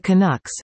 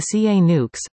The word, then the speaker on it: Canucks: The Canucks, sorry, the CA Nukes, didn't just fire Bruce Boudreau, but also Bruce Boudreau.